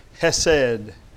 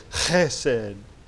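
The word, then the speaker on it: Hesed (חסד): That word is hesed in the Hebrew. Hesed. H-C-H-E-S-E-D. The Jews say it like this. Hesed. Hesed. Like they're clearing the throat.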